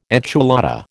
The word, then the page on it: enchilada: American English